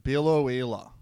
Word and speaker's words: Biloela: No, well, some fucking person on a Biloela forum was like, it's the suburb in Queensland that rhymes with tequila.